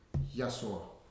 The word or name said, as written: Yasoa